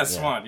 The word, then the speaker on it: Siuan: Um, and Siuan, Siuan, uh, I Swan, I think is how they pronounce it. – So Swan. – Yeah, Swan.